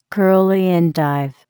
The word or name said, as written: curly endive